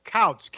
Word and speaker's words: Kautz: It's Kautz, K-A-U-T-Z. – K-A-U-T-Z. Okay, good. – Yeah, Kautz, yeah. Harold Kautz, H-A-R-A-L-D.